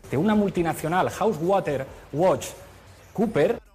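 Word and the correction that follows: PricewaterhouseCoopers: Que sepáis que es Price Waterhouse Cooper.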